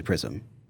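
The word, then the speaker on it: PR/SM: So it provides partitioning and all, we also call it LPAR, but it's officially Prism. So it's the thing that lets you have LPARs. – And it's spelled PRSM with a slash in the middle.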